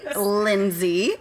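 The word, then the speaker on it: Lindsey: Lindsay. – We'll also tag it. – Berto. Yeah. Do I have to spell it? L-I-N-D.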